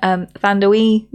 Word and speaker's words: Thandiwe: A bit timey-wimey, starring Hugh Jackman, Rebecca Ferguson, Thandiwe Newton, I believe is actually how you say her name.